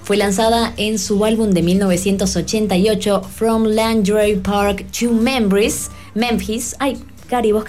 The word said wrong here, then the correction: Langley Park: que sos traductora, ¿cómo se pronuncia bien? – From Langley Park to Memphis.